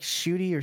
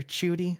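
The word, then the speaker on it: Ncuti: It's like Chuty or Chuty, something like that. Like, even though it starts with NC.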